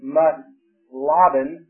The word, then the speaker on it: Muthlabben: To put an English spelling on a Hebrew word, it spells M-U-T-H-L-A-B-B-E-N. Mutlaban. And I'm probably slaughtering the Hebrew pronunciation, but because it's in English spelling, we have to pronounce it in the English way, but a Hebrew sitting here would think this morning, what a moron. But the English pronunciation is Mutlaban,